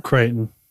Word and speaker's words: Crichton: Crichton.